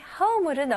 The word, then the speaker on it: home: 네, 우리나라 발음으로 그냥 home 이렇게 말하지만, 영어 모음들은 조금 더긴 느낌이 있습니다. 그래서 왜 많은 사람들이, 영어를 들을 때좀 느끼하게 들릴 수 있잖아요. 그 이유가 바로 그겁니다. 모음이 살짝 길어서 그래요.